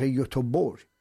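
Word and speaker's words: Göteborg: گی که آخر مثل شهر یوتوبور ما خب ما ایرانی ها با همدیگه که صحبت میکنیم گیم رفته بودم یوتوبوری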